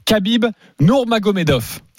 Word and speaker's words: Khabib Nurmagomedov: Khabib Nurmagomedov.